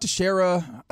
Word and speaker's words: Teixeira: Teixeira, no, you got it. Teixeira. Rangers, and then here's an even better one: Chandler Parsons. – Yeah, that's yeah, that's that's nasty business. The Mark Teixeira, uh, I get it.